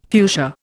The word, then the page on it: fuchsia: Indian